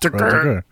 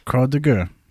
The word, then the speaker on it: Croix de Guerre: You have to pronounce it like that. – That's how it's pronounced. Croix de Guerre. Croix de Guerre. – Guerre. – Or in French, Croix de Guerre. Because we don't know how to say French things.